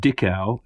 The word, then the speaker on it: Dickau: We pronounce it Dickau, and in the French, we pronounce it Dico, like a dictionary.